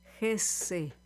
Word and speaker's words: Hesse: Así es, un alemán o un alemanófilo te diría que es Hesse.